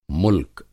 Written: मुल्क

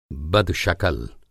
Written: बदशकल

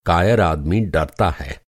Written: कायर

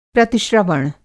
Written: प्रतिश्रवण

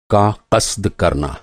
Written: क़स्द

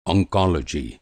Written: ऑन्कोलॉजी

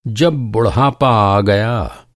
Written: बुढ़ापा

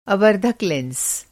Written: आवर्धक लेंस

